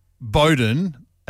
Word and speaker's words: Bowden: And I noticed that he pronounced the suburb in Adelaide, Bowden, as Bowden. – Bowden. – Bowden. It's spelled, so I understand. B-O-W-D-E-N.